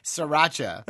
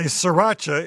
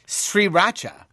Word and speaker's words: Sriracha: Sriracha. – Very good. The name, you say sriracha, is pronounced like Sri Lanka, with the accent on the first syllable, with a rising tone like asking a question.